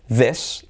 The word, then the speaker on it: this: Luego, acuérdate de diferenciar entre I corta, E, y I larga, these, y luego entre S sorda, que sería this, y luego S sonora, que sería these.